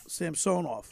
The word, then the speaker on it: Samsonov: Samsonov. – Or is it Samsonov? – Samsonov.